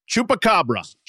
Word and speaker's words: Chupacabra: Chupacabra. – Chupacabra. – You're going to have, I knew you're going to have a tough time with that one. – I can't do anything. Chupacabra, the mythical vampire beast that hunts for blood of livestock or some other strange cryptid, which I don't know what it is.